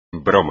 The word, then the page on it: broma: espagnol